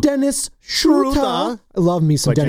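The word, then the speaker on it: Dennis Schröder: He's got the Undlot is the two little dots above the O, so it's the U pronunciation. So you have to say Dennis Schroeder.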